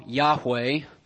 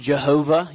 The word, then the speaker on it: Yahweh: We pronounce it now Yahweh, because it has four characters, YHWH. In older days, it was pronounced Jehovah.